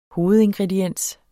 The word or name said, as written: hovedingrediens